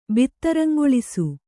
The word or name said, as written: ಬಿತ್ತರಂಗೊಳಿಸು